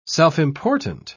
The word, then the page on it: self-important: British English